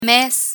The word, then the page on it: mes: Spanish